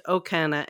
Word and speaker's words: Okona: Okana is spelled O-K-O-N-A, and it's pronounced Okana.